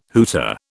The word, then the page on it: hooter: British English